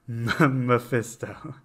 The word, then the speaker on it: Mephisto: So Mephisto, am I saying that right? – So think of like Mephisto. Mephisto. – Mephisto. Okay. So I don't give a fuck. You're good.